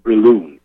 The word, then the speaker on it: Brillouin: The word is Brilloon. It's French. It's That's what it is. – Capital B-R-I-L-L-O-U-I-N.